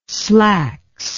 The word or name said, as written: slacks